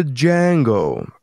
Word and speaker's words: Django: The Django. All right. – God damn. – The D is silent.